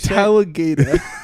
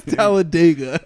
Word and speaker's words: Talladega: You say Talladega. Talladega.